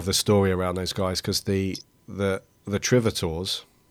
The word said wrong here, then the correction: Tritovores: Am I saying that right? Yes, go with it. – Yeah, the, no, the the Tritovores. Oh, that's it.